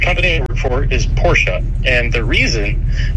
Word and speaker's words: Porsche: And the reason that the name is pronounced that way is that Porsche is a German company, and in the German language, oftentimes vowels at the end of words, particularly E's, are pronounced. So Porsche is the correct pronunciation of the name of the company and also the last name of the founder.